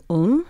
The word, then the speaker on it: Ulm: It begins with a 40,000-year-old object from the Ice Age, the Lion Man of Ulm. Am I saying that correctly? – Yes.